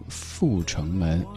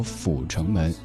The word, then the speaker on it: 阜成门: 比如说在某些地方，大家说，呃，这个教室，不好意思，教室，还像北京的地名阜成门，有人说阜成门，这都是错误的读音。